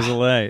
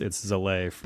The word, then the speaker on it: Zalay: I don't even pronounce it correctly based on like my like the phonetics of the country it's from. Like I'm from, or my name is from Hungary. – Okay. – And like it's spelled a little differently. I changed the spelling to make it a little bit more phonetic, but it should be Salai or something like that. But it's Zalay.